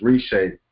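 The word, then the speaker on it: Reche: Even though Caldwell, whose first name is pronounced Rishay, was drafted by the Cincinnati Reds in 1998, his future was in football.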